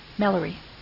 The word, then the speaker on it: Mellerey: M-E-L-L-E-R-E-Y. Millery.